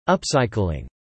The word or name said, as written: upcycling